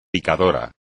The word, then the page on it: picador: Spanish